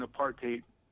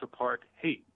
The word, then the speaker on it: apartheid: Why did South Africa teach Israel how to engage in apartheid activities? And some people say apartheid, but it's apartheid, apartheid, T-H-E-I-D, hate. It's hate, apartheid.